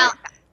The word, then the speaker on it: Malachi: Malachi.